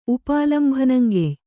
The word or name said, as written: ಉಪಾಲಂಭನಂಗೆಯ್